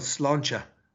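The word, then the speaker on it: sláinte: And Chris, just before you go, it's interesting how you pronounce that, because the first word means good health for us in Irish as well, Slancha. But interesting how you put the two of them together in Slangeva.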